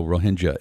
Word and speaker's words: Rohingya: I'm glad you corrected me because I had always heard Rohingya, so Rohingya is the better way of pronouncing it.